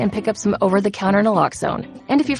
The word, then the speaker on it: naloxone: For the record, it's Naloxone. Nuh, like, is Naloxone addictive? Nuh. Lox, like, Naloxone keeps humans safe, like, locks keep bikes safe. And own, like, Naloxone can save lives in our very own communities.